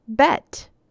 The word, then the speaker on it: Bet: The second letter of the Hebrew alphabet is pronounced Bet.